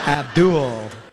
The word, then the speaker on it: Abdul: Well, you gotta say it like that, man. That's creepy. Abdul. – That's how it's pronounced.